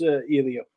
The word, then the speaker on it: Elio: And Helio, I don't know how to say his name. – It's Elio.